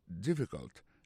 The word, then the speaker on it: difficult: Is it difficult? Difficult, là, ça veut dire difficile. Lié plus comme ça, D-I-F-F-I-C-U-L-T. Difficult. Il faut remarquer que l'accent tombé sur le premier syllabe.